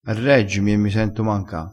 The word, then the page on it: manca: dialettale